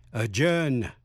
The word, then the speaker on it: adjourn: Số 3 là những chữ sau đây đi câm. Nguyên âm A đứng trước phát âm như E hay Ơ. Thí dụ, adjective, tính từ hay là tính từ. Adjourn, A-D-J-O-U-R-N. Adjourn là hoãn, ngưng họp. A judge là phán quyết. A-D-J-U-D-G-E, a judge, xét xử, tuyên án. Adjunct, adjunct, A-D-J-U-N-C-T, an adjunct professor, giáo sư dạy giờ ở đại học.